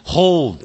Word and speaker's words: hold: Hold 也是国人的发音的时候不太注意的。因为它是 H-O-L-D, 是不是一个 L? 所以你念的时候那个舌头要抵上去 ,hold, hold, 不要念成 hold, 啊小地方就知道你英文讲的到是不是非常的道地或者叫地道啊。